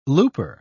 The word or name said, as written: looper